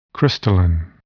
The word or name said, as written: crystalline